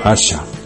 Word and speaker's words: Ayesha: A través de las conversaciones entre ambos, el lector sabrá que el nombre de She es Ayesha, pronunciado Asha, y que tiene 2000 años de edad porque ha alcanzado la inmortalidad en el fuego de la vida.